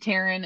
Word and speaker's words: Tarwyn: It's spelled Tarwyn. It's said Tarwyn.